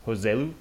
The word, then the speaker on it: Joselu: You know, got a couple of Spaniards coming to the game, Hesse and Joselu.